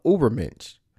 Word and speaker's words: Übermensch: Quote, inspired by Darwin and examinations of his idea, German philosopher Friedrich Nietzsche introduced the idea of Übermenschen. I can't pronounce that word. Hold on a second. Let me look it up. Give me 1 second. Okay, it is Übermensch.